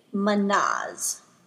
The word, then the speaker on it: Mannaz: The last rune that we will be covering today is Manaz. It is spelled M-A-N-N-A-Z, Manaz.